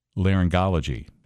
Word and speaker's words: otorhinolaryngology: Pediatric otorhinolaryngology. – Perfect. – You specialize in pediatric otorhinolaryngology.